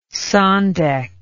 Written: sandek

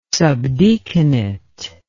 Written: subdeaconate

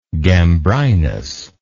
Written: gambrinus

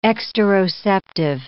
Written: exteroceptive

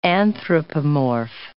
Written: anthropomorph

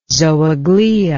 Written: zooglea

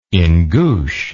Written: Ingush

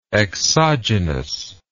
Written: exogenously

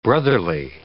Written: brotherly

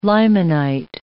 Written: limonite

